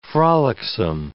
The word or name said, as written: frolicsome